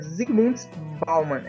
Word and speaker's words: Zygmunt Bauman: é Zygmunt Bauman, é isso? – Isso mesmo.